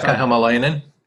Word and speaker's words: Hämäläinen: Hämäläinen.